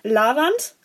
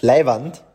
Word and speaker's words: leiwand: Äh, wahrscheinlich werde ich es jetzt falsch aussprechen. Lavand, richtig oder falsch? – Leiwand, meinst? – Ja. – Das ist Leiwand.